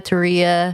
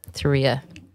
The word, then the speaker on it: Turiya: Well, I go to like number two, but Maria, Turiya. – Yeah. – For people to pronounce it better. But yeah. – Turiya.